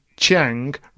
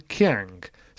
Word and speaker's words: Qiang: it also should have been Chang rather than Qiang. So we've got that one wrong too. Anyway. – Chang Wong. Yes, Chang.